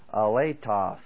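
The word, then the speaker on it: aletos (ἀλάλητος): Here we have the word A-L-A-L-E-T-O-S. The pronunciation is aletos.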